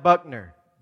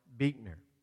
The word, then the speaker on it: Buechner: It looks like Buckner, but it is pronounced Buechner.